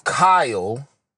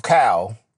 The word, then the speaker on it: Kyle: I know I say 12 Cal. That's what I say. I say Cal. The Y is silent. But technically it's 12 Kyle.